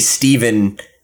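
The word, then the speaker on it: Stjepan: So it will be written by Joshua Williamson, and the art will be by Stejepan Sejic, I believe is how you say it. – It's like, that's the, I forget where he's from, but that's the way you say Steven in that language.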